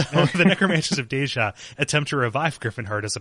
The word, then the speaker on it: Deyja: I kind of want to give it a, a, a, soft J. So Deyja.